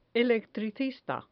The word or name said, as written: electricista